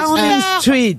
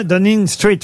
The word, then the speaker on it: Downing Street: Downing Street. – Downing Street. – Oui, je l'ai dit. – Downing Street. – Ten Downing Street, mais je voulais que ce soit bien prononcé.